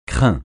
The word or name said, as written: crin